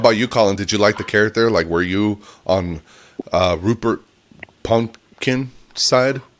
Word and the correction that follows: Pupkin: Did I say it right? – Pumpkin? – It was like Pupkin.